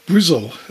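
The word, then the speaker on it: Bristol: Now for our next item, we go to Brizzle, as it's often pronounced.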